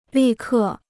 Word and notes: 立刻: lì kè

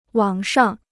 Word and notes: 网上: wǎng shàng

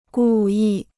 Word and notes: 故意: gù yì

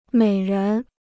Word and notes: 美人: měi rén

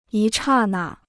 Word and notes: 一刹那: yī chà nà

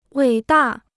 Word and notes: 伟大: wěi dà

